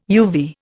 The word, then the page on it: uvea: Indian